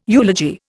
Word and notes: eulogy: Indian

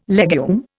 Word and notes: legume: Indian

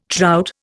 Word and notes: trout: Indian

